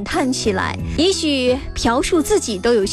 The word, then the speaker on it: 朴: 很多人喜欢他的歌，也喜欢他的音乐，更多的人喜欢叫他朴树。其实从正确的读音来说，这个字用作姓的话，应该叫朴，朴树的。